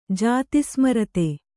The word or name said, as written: ಜಾತಿಸ್ಮರತೆ